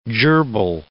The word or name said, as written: gerbil